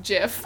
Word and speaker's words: GIF: Although I know we've gotten like some complaints that say like, I'm sorry to break it to you, but it's pronounced GIF. – Oh yeah. I feel like that's a technical way, but I just like, I've been pronouncing it GIF my entire... – And it's one of those things you can say either way.